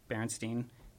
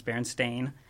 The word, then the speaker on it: Berenstain: I don't know if it's most people, but a lot of people uh, spell it Berenstein, E I N, and also like pronounce it Berenstein Bears. Sorry. sorry. Um, and uh, it turns out that that is not how it's spelled or how it's pronounced. It's Berenstain.